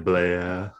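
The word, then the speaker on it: Blais: Getting Blazer back, which is always funny to me because you don't pronounce the S, and it's always Blair.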